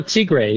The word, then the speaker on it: Tigre: I hope I'm saying this correctly. The Tigris, it takes you... – Oh, Tigre, Tigre. Tigre.